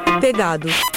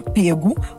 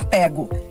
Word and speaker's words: pego: As duas formas estão corretas. Com o verbo pegar, inclusive, aceitam-se três formas de particípio. Você pode dizer, eu tinha pegado, eu tinha pego ou eu tinha pego. Essa forma aberta, pego, já é mais coloquial.